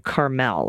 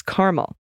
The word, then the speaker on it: Carmel: you use the pronunciation Carmel, like in California, but it's Carmel, like the flavor.